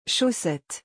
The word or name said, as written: chaussettes